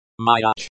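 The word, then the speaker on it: Myach (мяч): Myach, the stress is on the first syllable, M-Y-A. Meaning, throw the ball. Variations. Verse. M-Y-A.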